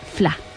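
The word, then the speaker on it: Fleadh: Pero bueno, pues eso, que ya hay gente que ya sí sabe cómo se dice, pero para los que no lo sepan se dice FLA solamente. – ¿No? ¿Iris FLA? – Otra cosa, Iris FLA, ya está. – Iris FLA. Oye, y, bueno, el Iris es a lo mejor más sencillo para aquellos que han estudiado inglés, ¿no? Por la I, que ya sabemos que se pronuncia como I, pero lo del FLA ya tenemos ahí más. – Porque rápido significa fiesta en gaélico, ¿vale?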